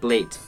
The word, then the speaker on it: blat: Okay, this is pronounced blate. B-L-A-T. – Screw you. Single syllable blate.